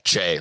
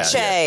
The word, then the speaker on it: Che: Did I say it right? – Che, like a hard Che. C. Yeah, Che.